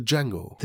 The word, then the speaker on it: Django: Der ursprüngliche Django. The D is silent.